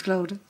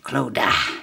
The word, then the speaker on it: Clodagh: Her name's Clota. Shake of it. – How do you spell Clota? – C-L-O-D-A-G-H. Clota. And then my son is called Tig, which is spelled... – Q-W-T-F-P.